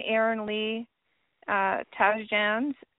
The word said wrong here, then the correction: Tasjan: Taz Jan. That's how you say it, Taz Jan.